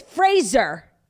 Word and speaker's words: Fraser: So he says, instead of a similar pronunciation similar to the 90s sitcom Frasier, Frasier says his name actually rhymes with Razor. So it's Fraser.